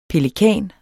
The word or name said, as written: pelikan